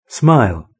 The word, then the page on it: smile: British English